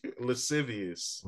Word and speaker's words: lascivious: How do you say it again? Lascivious? – Lascivious. – Lascivious. – Yeah. – Oh, it is lascivious. Why they got that C in there for? That shit's stupid.